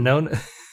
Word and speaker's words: Wynonna: Which they mispronounce, I'm fairly certain. Winona. Well, I think it's all up for interpretation because I would pronounce it Winona. It is Winona.